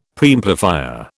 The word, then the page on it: Preamplifier: British English